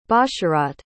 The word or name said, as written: basharat